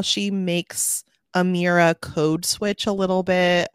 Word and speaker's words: Emira: Even Amira's name is spelled with an E. And so I'm looking at it right now and I didn't even realize that, but it's pronounced Emira.